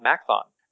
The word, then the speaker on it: Maxthon: I haven't looked recently, but I know that in China, for example, one of the most popular browsers is a browser called Macthon. It's spelled Maxthon.